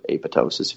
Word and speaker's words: apoptosis: But autophagy and apoptosis, or apoptosis, if you want to say it, the alternative pronunciation, are basically the way your body gets rid of or cleans up damaged tissue.